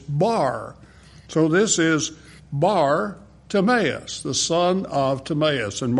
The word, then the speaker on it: Bartimaeus: As he was leaving Jericho, Bartimaeus, you got that pronunciation right on, spot on there, brother. Bartimaeus, the word bar is Aramaic for son of.